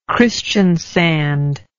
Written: christiansand

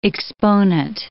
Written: exponent

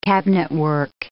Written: cabinetwork

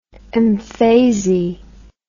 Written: mfesi

